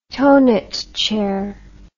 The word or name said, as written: thonet chair